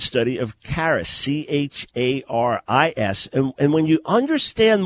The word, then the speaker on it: charis: And I'm going to leave the Greek word in charis. It's spelled C-H-A-R-I-S, as in charisma or charismatic.